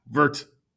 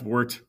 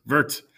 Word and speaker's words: Wirt: Wirt. Not Wirt. Wirt. But both of his parents die before he's eight. And his uncle becomes his guardian. Jasper. Wirt.